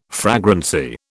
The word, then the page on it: fragrancy: British English